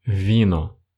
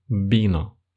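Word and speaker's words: vino: Например, испанец не скажет слово «вино», Испанец скажет слово «бино», хотя пишется она через букву УВ.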